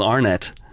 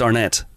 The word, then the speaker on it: Arnett: I realised that I said Will Arnett.